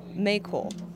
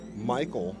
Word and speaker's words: Mayckol: Guapi Maykol. – Okay. – Maykol Guapi. – Somewhat close. It's actually Michael Guaype. – Not close. – In my defense, his name is spelled M-A-Y-C-K-O-L.